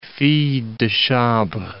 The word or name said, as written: fille de chambre